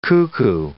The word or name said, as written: cuckoo